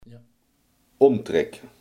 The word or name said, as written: omtrek